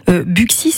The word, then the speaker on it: Buxy: Buxy serait le seul nom de commune situé dans l'ancienne province bourguignonne comportant un X au milieu, hein, où ce X se prononce X.